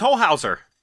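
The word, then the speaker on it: Holzhauer: He also says, I would recommend watching the two-part film theory YouTube videos about James Holzer's, is that how you pronounce his name? James Holzer? His time on the show and how they got him gone. So I'm not sure. I'd have to look. I don't remember who James Holzer is. Do you have any idea? – I don't immediately know. What show? – Okay. Yeah. I don't know. He's apparently a... Oh, he was on Jeopardy. He's known for being on Jeopardy. – Oh, James Holhouser. – Oh, that's how you pronounce that? – Yes.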